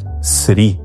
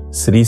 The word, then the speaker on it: Sri: Quem quiser aprofundar isso, existe um famoso estudo de um teólogo leigo chamado Edward Sri. Sri se escreve S-R-I.